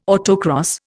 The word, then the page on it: autocross: Indian